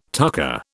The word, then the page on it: tucker: British English